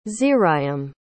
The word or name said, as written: zeraim